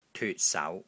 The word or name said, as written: TYUT3 SAU2